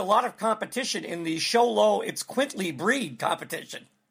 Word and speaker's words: Xoloitzcuintli: He is it's supposedly pronounced Sholo It's Quintly, which is XOLOITZCUINTLI.